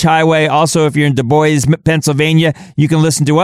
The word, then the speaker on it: Du Bois: Du Bois? – Sure, why not? – Du Bois. – There you go. – D-U capital B-O-I-S. Du Bois.